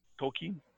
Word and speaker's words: Tolkien: Tolkien? – Yeah. Well, okay, you pronounced it right.